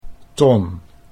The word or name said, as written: Ton